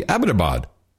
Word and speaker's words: Abbottabad: And he's going Abbottabad. Everyone's going Abbottabad. And one guy says, well, I was actually in Abbottabad. I'm like, yeah, I bet you were because you pronounced it correctly. Abbottabad.